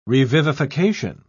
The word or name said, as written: revivification